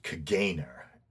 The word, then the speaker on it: Caganer: But the tradition is that of displaying a figure known as, it's spelled Caganer, C-A-G-A-N-E-R, but I believe it's pronounced Cagane.